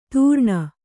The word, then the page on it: ತೂರ್ಣ: Kannada